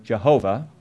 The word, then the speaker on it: Jehovah: which we pronounce Yahweh or Jehovah,